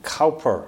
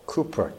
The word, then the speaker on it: Cowper: It's interesting, we were singing earlier, "Oh, for a Closer Walk with God" by William Cowper, or Cooper, depending on how you like to pronounce it.